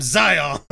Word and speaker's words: Zion: I thought everyone pronounced it Zion. Because I was reading an article and they were like, Mormon, they called it Zion, pronounced like lion.